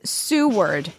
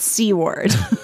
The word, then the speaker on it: Seward: So this is actually a listener via Instagram, and it's, well, one listener wrote in and said, I don't know, but C-word is Seward, not C-word.